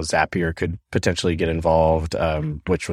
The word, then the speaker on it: Zapier: And yes, it is Zapier, rhymes with happier.